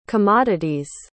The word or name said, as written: commodities